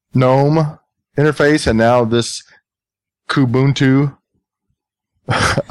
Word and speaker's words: Kubuntu: I would say Kubuntu. – How about? – Chris would say Kubuntu. – Yeah. Kubuntu. That's how I'm going to say it from now on. Kubuntu. So Kubuntu obviously is using the KDE interface versus the GNOME or the GNOME or the GNOME.